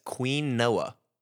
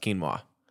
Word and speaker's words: quinoa: And I was like, no, that's quinoa. It's pronounced quinoa.